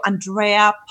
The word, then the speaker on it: Andrea: Just go to Andrea Palton Coach.